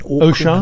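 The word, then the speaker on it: Auchan: And in fact, the owners of Decathlon, which is a French privately held group, also has Leroy Merlin and the, is it Orkun? – Osha.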